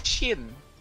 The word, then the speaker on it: Shin: You may call me Shin. – Shin? – It's spelled with an I. Finally, nice to meet you. – C-H-I-N? Chin? – Shin. – S-H. Huh? – S-H-I-N.